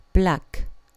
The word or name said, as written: black